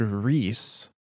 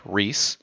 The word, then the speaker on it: riis: the word riis um r i i s in this spelling it shares a very shares the same pronunciation, I believe, as well as very similar spelling to the word Rhys in uh, ancient Nordic, which refers to like nature and gardeners.